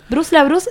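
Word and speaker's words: Bruce LaBruce: Bruce La Bruce se escribe Bruce, como Bruce Willis, y la Bruce es como suena.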